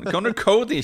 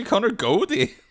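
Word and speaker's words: Conor Coady: Con Connor Cody, ikke Connor Godie.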